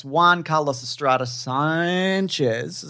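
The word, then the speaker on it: Juan Carlos Estrada Sánchez: So it's Juan Carlos Estrada Sanchez. Is that how you pronounce it? – Yeah, you do it like exactly like that, yes.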